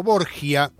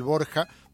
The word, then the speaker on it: Borja: Hemos tenido emperadores romanos que han nacido en España, bueno, en la península, y también el cardenal español Alonso de Borja, que lo conoce la mayoría de la gente como Borgia, pero lo de Borgia es porque es la pronunciación italiana para el Borja valenciano.